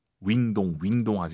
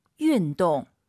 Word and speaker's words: 运动: 윈동 하지 마시고 역시 와유 윤 입술을 동그랗게 말면은 만사 해결입니다. 윈동 다시 들어볼게요. 운동 발음. – 运动。